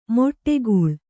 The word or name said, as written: ಮಡುಗೊಳ್ಳು